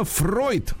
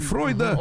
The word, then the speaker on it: Фройд: И в 1895-м Анна Фройд родилась вот, дочь Зигмунда дочь, Фройда. Он на ней опыты ставил. – Да, именно так надо произносить его фамилию.